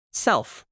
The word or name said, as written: self